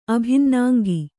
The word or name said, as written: ಅಭಿನ್ನಾಂಗಿ